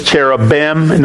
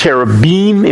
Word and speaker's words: cherubim: We pronounce it in English cherubim. No, it's cherubim, if we're going to be correct to the Hebrew language.